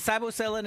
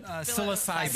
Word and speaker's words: psilocybin: Well, is it psilocybin? Is that how you say it? – Psilocybin.